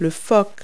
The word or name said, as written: jib